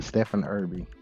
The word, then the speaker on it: Stephen Erby: It's Stephen. Well, yeah, you got it right. Stephen Erby.